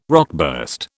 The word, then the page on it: rockburst: British English